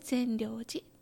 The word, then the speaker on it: 善良寺: はい、横浜の戸塚にあるお寺、えーとですね、善悪の善、よしという字に終了の量と書いて善良寺と読みます。